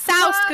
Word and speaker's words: soused: You pronounce it soused, Google says.